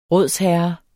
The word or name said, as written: rådsherre